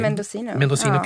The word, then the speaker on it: Mendocino: jag vet inte vad jag ska uttala det, men det är Mendocino County. – Mendocino County, norra Kalifornien.